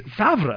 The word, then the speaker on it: Favre: You guys pronounce it Favre, even though it's like... It's Favre. It's like it's a French word, and the R and the V come in the opposite order.